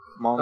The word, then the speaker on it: Hmong: I think the H is silent. – Hmong.